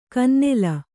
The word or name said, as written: ಕಿನ್ನೆಲ